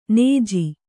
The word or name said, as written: ಞ